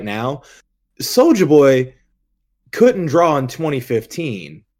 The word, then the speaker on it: Soulja: Yeah, you it's Soulja. Say it right.